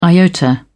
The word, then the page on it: iota: British English